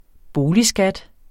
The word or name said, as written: boligskat